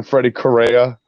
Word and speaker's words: Freddy Correa: How you doing, Freddy Correa? – Yes, Freddy Correa.